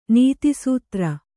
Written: ನೀತಿಸೂತ್ರ

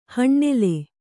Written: ಹಣ್ಣೆಲೆ

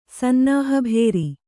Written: ಸನ್ನಾಹಭೇರಿ

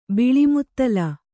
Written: ಬಾಳ್ಮೊದಲ್

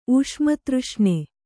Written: ಊಷ್ಮತೃಷ್ಣೆ

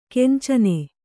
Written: ಕಾಂಚನಿ

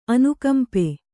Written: ಅನುಕಂಪೆ

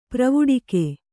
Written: ಪೆರ್ವಡುಕೆ

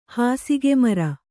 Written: ಹಾಸಿಗೆಮರ

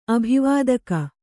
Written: ಅಭಿವಾದಕಿ

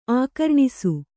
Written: ಆಕರ್ಣಿಸು

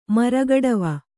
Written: ಮರಗಡವ

